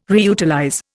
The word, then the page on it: reutilize: Indian